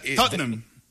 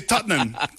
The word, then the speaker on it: Tottenham: Tottenham. – Tottenham. – Son dos sílabas. – Ajá. Es difícil, ¿no? – Nosotros los británicos. Pero... – ¿Solo dos sílabas? – Sí, Tottenham.